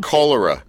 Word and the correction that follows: coloratura: Yeah, coloratura. – Coloratura. – That's right.